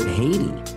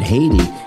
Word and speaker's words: Haiti: So that is, you know, the word Haiti. Haiti is phonetically the same. It's a French word, but it's phonetically the same as Hades.